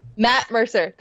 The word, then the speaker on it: Matt Mercer: And I feel like you could probably take on Mac Mercer. Did I say his name right? – Matt Mercer.